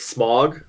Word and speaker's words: Smaug: In my head, it just comes off as smog. Like, like the air pollution.